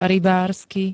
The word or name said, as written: RYBÁRSKY